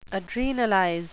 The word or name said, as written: adrenalize